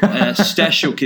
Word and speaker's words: Stasiuk: Stasiuk is the Stasiuk. Okay, yeah, apologies. – Ukrainian pronunciation.